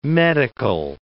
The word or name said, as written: medical